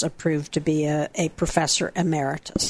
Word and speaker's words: Emeritus: Emeritus. – Emeritus.